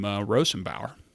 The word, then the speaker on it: Rosenbauer: And it's funny because there are actually some people who can correctly pronounce my last name, Rosenbauer.